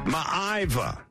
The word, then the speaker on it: Maiava: Now, there's a different pronunciation going on, too. – And I even wrote it down phonetically, and I still couldn't get it, yeah. – Mayava is another pronunciation.